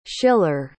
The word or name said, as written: shiller